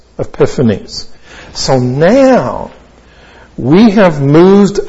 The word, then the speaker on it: Epiphanes: This is a prophecy of a man whose name was Antiochus Epiphanes. And Antiochus is A-N-T-I-O-C-H-U-S. A-N-T-I-O-C-H-U-S. His second name is Epiphanes. E-P-I-P-H-A-N-E-S. E-P-I-P-H. A-N-E-S.